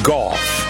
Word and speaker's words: Gough: It is G-O-U-G-H is pronounced golf.